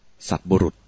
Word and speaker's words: สัตบุรุษ: สัตบุรุษสัตบุรุษแปลว่าบัณฑิตสัตบุรุษเนี่ยสะกดว่าสอเสือมนกาศตอเต่าคนไทยอ่านผิดกันว่าเป็นสัตตบุรุษจริงๆต้องอ่านออกเสียงว่าสัตบุรุษ